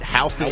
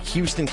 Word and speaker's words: Houston: Houston County, you better say it right. Houston. If you ever call it Houston County while you're in Houston County, they will quickly let you know that you mispronounced the name of their county.